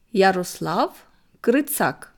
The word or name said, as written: Yroslav Hrytsak